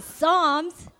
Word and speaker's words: Psalms: Palms. Sorry, guys. Okay, Psalms. The P is silent, okay?